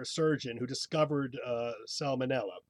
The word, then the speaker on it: salmonella: Okay, salmon is not with an L. How do you pronounce the bacterial disease? S-A-L-M. – We talked about this once. That's the problem. Salmonella. – Let's see if Fletcher remembers. Fletcher? – Yeah, salmonella is how I would pronounce it.